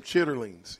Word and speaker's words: chitterlings: Did I say that right? – Some chitterlings. You want us to bring you some chitterlings? – I don't think so.